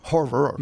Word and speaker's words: horror: Horror. – Yeah, horror. – Gotcha. – Two syllables.